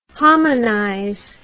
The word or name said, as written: hominize